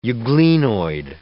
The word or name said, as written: euglenoid